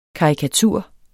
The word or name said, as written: karikatur